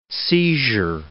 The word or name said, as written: seizure